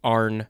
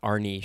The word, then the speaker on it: Arne: The guy's name, it might be Arn Cheyenne, not Arnie Cheyenne, just based on the spelling you've got here. – You can spell Arnie, A-R-N-E.